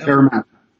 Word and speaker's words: Parramatta: Is that Parramatta.